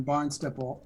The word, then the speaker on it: Barnstaple: My wife and I traveled to England in 2019 to attend the first Restoration of All Things conference in Barnstaple, England, which I did pronounce correctly.